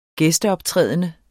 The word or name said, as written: gæsteoptrædende